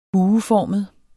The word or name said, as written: bueformet